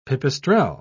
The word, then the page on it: pipistrel: British English